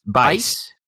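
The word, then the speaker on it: Buice: Laney Busey. – Bice. – That's how you spell it? Okay, I'm so sorry. Yeah. That is under no way, shape, or form how I thought that was going to be pronounced.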